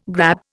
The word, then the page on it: grab: Indian